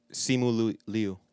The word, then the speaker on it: Simu Liu: Also, to the people that looked at the clip of, how do you say his name? Shimon? – Simu Liu. – There we go.